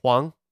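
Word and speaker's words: 黄: Huang.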